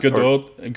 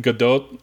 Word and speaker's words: Gadot: Is it Gal Gadot? – No, it's Gadot. Gadot. Well, because it's Israeli, there's some aspirated, there's like an aspirated T at the end, sort of.